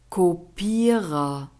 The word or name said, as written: Kopierer